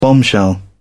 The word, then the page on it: bombshell: British English